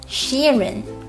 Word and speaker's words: Sheeran: 他名字的读音是 Ed Sheeran Double E 发长元音 E 的音另外也要注意 ed 不要读成了 ed 或者 ed 哦